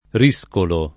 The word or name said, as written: riscolo